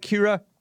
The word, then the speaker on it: Kira: Am I pronouncing your name right, Kira? – Yeah.